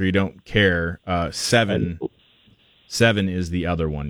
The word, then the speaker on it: Sevin: It's it it's seven, but it's spelled wrong. It's S E V I N.